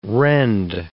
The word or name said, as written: rend